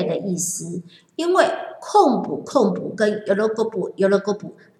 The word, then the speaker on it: 昆布: 那昆布的话，在日文当中，我们就会把它念作控布。